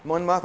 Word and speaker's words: Monmouth: Monmouth? Okay, I'm afraid to not say the British words properly now. Thomas of Monmouth was the only record of the event.